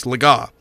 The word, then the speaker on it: Legas: You know, the announcer saying, Cooper Legas, or something like that, where he pronounced his name wrong because he hadn't gotten that far in the pronunciation guide. Cooper Legas, or something like that. But, you know, it's Legas.